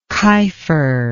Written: keifer

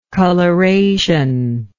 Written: colorationally

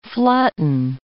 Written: flatten

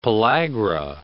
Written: pellagra

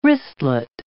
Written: wristlet

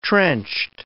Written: trenched

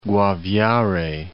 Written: Guaviare

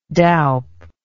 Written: doup

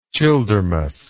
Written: Childermas

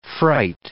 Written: fright

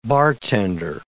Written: bartender

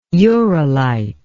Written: uralite

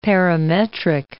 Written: parametric

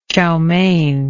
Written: chowmein

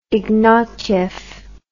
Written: ignatyef

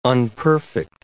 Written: unperfect

